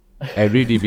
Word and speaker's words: Eredivisie: First of all, he says you're saying it totally wrong. Eredivisie.